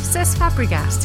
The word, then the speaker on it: Cesc Fabregas: Cesc Fabregas.